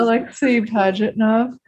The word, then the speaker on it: Alexei Pajitnov: Alexei Pajitnov.